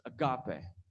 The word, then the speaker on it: agape: It's agape. Someone say agape. It's not agape, right? Agape. You got to get your Greek pants on and pronounce things weird.